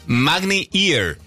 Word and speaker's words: Magni Ear: Magni Hear. – Magni Ear. – Bueno, yo pronuncio a mi forma. – Ah, con razón, porque here es otra cosa, es de aquí. – Ear se escribe.